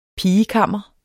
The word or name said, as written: pigekammer